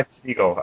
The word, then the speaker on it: Segall: Yeah, Mark, you're a big Ty Segall, Segal fan. Ty Segall, I believe it's pronounced.